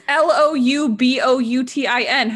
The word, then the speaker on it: Louboutin: It's not La. It's still Lou. – Everyone says La Vuitton. – It's Christian Louboutin. – Everyone says Christian Louboutin. – It's Louboutin. How is that La? – Everyone says it like that.